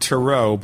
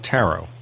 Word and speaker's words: tarot: You call it tarot, but most people call it tarot. – Uh, it's pronounced both ways. I usually say tarot, but some people say tarot.